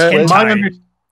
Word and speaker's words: hentai: Are you guys familiar with the term hyente? – I think it's pronounced hentai. It's pronounced hentai, I think, Kirk.